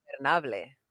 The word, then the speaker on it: Ingobernable: Ingobernable.